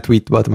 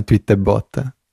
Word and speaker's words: Tweetbot: No, questa è una battuta strana perché c'è, c'è qualcuno che ha la pronuncia un po'. Non è Tweetbot, ma è Tweetbot.